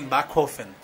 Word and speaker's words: Backhofen: É, é Backhofen. – Backhofen. Backhofen. Peraí, como é que é a pronúncia é, correta? Mais ou menos assim, Backhofen.